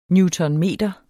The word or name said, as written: newtonmeter